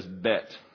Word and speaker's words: bet: Notice it says bet. That's the way you pronounce it, not Beth. It's pronounced bet. It's a hard T.